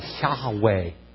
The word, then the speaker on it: Yahweh: If you look in the Hebrew, it says, you can actually pronounce it Yah-ha-ve-heh, because of the way the vowels are in Hebrew. And it's almost like four different words, but we pronounce it Yahweh.